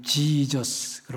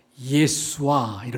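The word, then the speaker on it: 예수와 (ישוע): 서양 사람들은 지저스 그러죠. 본래 이스라엘 본토인들은 예수 그러지 않고요. 지저스 그러지도 않고요. 예수와 이렇게 말합니다. 예수와. 한번 다 같이 해보세요. 예수와. 네. 그러니까 이게 본토 발음이에요.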